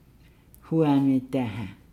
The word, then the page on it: daahaa: Nukuoro